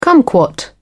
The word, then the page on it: cumquat: British English